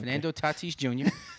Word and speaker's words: Fernando Tatis Jr.: But this comes about because Fernando Tatis Jr., my new favorite player in Major League Baseball. – That how you pronounce it? – That's how you pronounce it. Okay. Fernando Tatis Jr. That's my dog right there, number 23.